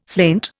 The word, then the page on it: slainte: Indian